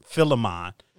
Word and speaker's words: Philemon: it's Philemon. Philemon, is that how you pronounce it? Always mispronounce it. Philemon, mm-hmm.